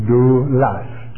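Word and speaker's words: doulas (δοῦλος): The word in the Greek is the word doulos. It's pronounced doulas, doulas. And it means a slave.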